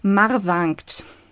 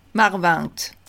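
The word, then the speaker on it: Marvingt: Also laut Internet Betonung auf der ersten Silbe und ein Nasal, also Marwankt. – Marwankt.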